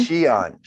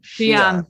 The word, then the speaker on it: Xi'an: X-I-A-N. – Oh, Xi'an. – Xi'an. Xi'an, yeah. – X is spelled as a S-H.